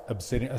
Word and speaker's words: Absideon: Absideon. Spell Absideon, because I had a tough time spelling that word. So it gets confused with the stone Absideon, which it's pronounced the same, but it's spelled Absideon.